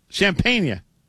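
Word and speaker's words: Champagnie: How are we pronouncing this guy's name? Is it Champagne or Champagne-ya? What? Champagne-ya. I've heard it pronounced like 17 different ways. Well, it's 24. – I'm pretty sure it's Champagne. – Champagne. – Oh, Champagne. Yeah, that is how I've heard it.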